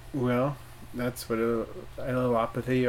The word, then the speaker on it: allelopathy: Allelopathy. – I like the one you did better. – What did I just say? – Allelopathy. – Allelopathy. – Because it rhymes with homeopathy.